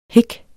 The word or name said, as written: hik